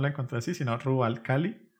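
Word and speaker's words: Rub al Khali: Ah, bueno, digamos en su idioma, en árabe supongo, es Rubal Cali. – Ok. – Eh, que digamos, en el juego lo pronuncian como Rubal Cali, así más rápido.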